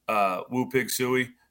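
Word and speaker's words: Woo Pig Sooey: The one that would see would be fun, Woo Pig Suey. Is that, did I say that right? – Yeah.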